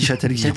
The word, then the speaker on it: Châtel-Guyon: Guyon, on dit Châtel-Guyon. Ah oui, il faut faire attention à la prononciation.